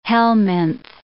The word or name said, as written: helminth